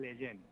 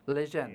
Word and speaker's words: Lejeune: y ayer nos terminamos de, de bueno, de dar cuenta de cómo se, se pronunciaba. Es Jerome Leyen. Leyen.